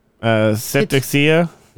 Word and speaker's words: Cetacea: Cetacea.